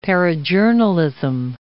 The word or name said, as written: parajournalism